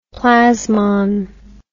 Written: plasmon

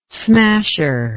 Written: smasher

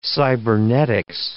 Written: cybernetics

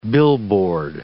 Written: billboard

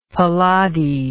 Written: palade

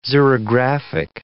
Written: xerographic

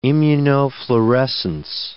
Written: immunofluorescence

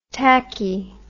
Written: tachy

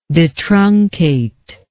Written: detruncate

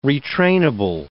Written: retrainable

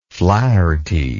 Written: flaherty

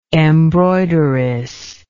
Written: embroideress

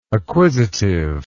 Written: acquisitiveness

